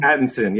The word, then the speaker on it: Pattinson: Pattinson, yes.